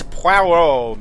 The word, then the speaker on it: Poirot: It's pronounced Shives, Mr. Perot. – And it is Poirot, Mr. Chavez.